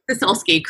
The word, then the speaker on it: Sosulski: And I believe I said your last name wrong. It's Sosulski. – Sosulski, correct.